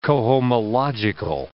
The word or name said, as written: cohomological